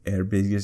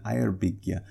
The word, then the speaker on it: Eyrbyggja: And then we finally worked it out, and she said she pronounced it Eyrbyggja. – She went Eyrbyggja. Yeah. So she had a hard G at the end, but she also doesn't say air. She says ire, right? – Yeah.